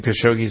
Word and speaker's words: Khashoggi: Is that his name, Khashoggi? Isn't that how you pronounce his name? – Mm-hmm.